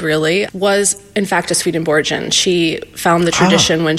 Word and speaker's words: Swedenborgian: I have so many questions, okay, as many people do, about what Swedenborgian is the right pronunciation.